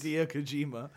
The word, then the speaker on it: Hideo Kojima: which is made by Hideo Kojima and... Did I say that wrong? Am I racist? – Hideo Kojima.